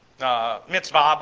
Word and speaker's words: mitzvah: But when you listen to Jewish people speak, you'll hear them pronounce it as a V. Mitzvah, bar mitzvah.